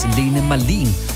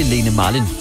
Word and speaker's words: Lene Marlin: Jeg har først for ganske nyligt fundet ud af, at hendes navn udtales Lene Marlin, og ikke Lene Marlin, som jeg ellers har siddet masser af gange her i radioen og sagt.